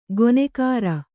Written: ಗೊನೆಗಾರ

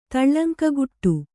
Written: ತುಳುಂಕುಗುಟ್ಟು

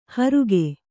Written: ಹರಿಗೆ

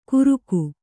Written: ಘುರುಕು

